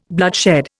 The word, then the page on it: bloodshed: Indian